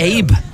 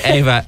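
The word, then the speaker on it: Aoibhe: Guys, how do you pronounce that name? – Is that Abe? Anyway, Ava.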